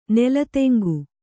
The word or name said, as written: ನೆಲತೆಂಗು